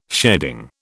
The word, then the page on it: sheading: British English